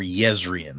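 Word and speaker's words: Jezrien: But when he arrives, only Jezrian is there. Or Yezrian. I'm sorry, it's the soft J in for these people.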